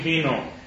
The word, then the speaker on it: wino: Chleb i wino. – Chleb i wino. – Wino. How do you say it? – Wino. – Wino. Okay? Just like today.